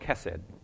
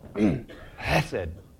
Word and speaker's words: chesed: It's called chesed. And I never pronounce it right because I don't want to spit on the Landers. chesed. You kind of have to get something up to do it. It's C-H-E-C-E-D. Chesed.